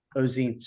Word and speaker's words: Ozenne: Ozenne.